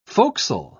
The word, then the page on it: forecastle: British English